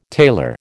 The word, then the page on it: tailor: American English